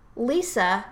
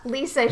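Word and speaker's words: Liesa: It's Lisa, Germanic pronunciation.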